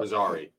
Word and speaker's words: Fezzari: I always mispronounce it. F- uh, for for Faraz- Farazi. Oh, I didn't know they were coming. I mean, we should just pick them up. – Fezzari, Fezzari, Fezzari, Ferrari.